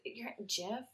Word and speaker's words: GIF: I'm on the GIF side. No, it's, GIF? Mm-hmm. Really? – Yeah, like giraffe.